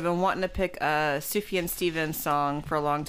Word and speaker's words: Sufjan: Sufjan? – I actually wrote Surgeon Stevens. – Sufjan. The J is a Y sound.